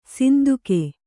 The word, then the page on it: ಸಿಂದುಕೆ: sinduke